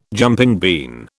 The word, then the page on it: jumping bean: British English